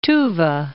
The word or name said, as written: Tuva Republic